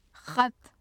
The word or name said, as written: xat